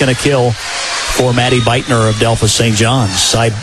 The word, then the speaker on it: Buettner: Beitner spells her name B-U-E-T-T-N-E-R, but pronounces it Beitner.